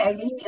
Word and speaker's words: Eileen: the way, Eileen is spelled with an E.